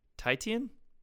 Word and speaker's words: Titian: Titian? – Yeah, am I saying that right? T-I-T-I-A-N.